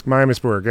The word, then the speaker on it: Miamisburg: Miamisburg, yeah.